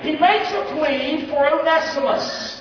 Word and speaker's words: Onesimus: But he pleads for a man named Onesimus. Or if you're from the South, one Simus. But the proper way to say it is Onesimus.